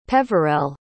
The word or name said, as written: peverell